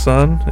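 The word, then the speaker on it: Sunn O))): it just Sun, or is it Sun O? – It's pronounced Sun. It's spelled Sun O.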